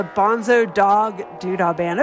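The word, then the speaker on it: Bonzo Dog Doo-Dah Band: And at the top of the set, the Bonzo Dog Doodah Band.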